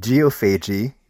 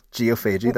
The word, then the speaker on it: Geophagy: Geophagy, right? – We talked about that's four syllables. Geophagy, that's four syllables.